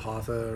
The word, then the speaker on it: hatha: You know, three different levels of hatha or vinyasa. – That's a hatha. – Yeah, that is correct. Hatha is the correct pronunciation.